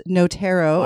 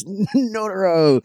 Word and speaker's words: Notaro: I argued for a couple of weeks about the pronunciation of the new name. It is Notaro. – I was even ready to go fisticuffs on that. I'm like, no, it's Notaro.